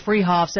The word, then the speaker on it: Freehoff's: They're spelled Freehoff's, pronounced Freehoff's, at 33rd and Arapahoe in Boulder.